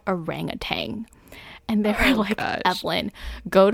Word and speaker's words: orangutan: If you ever go to Malaysia and see an orangutan, now you know how to pronounce it in Malaysia. Orangutan.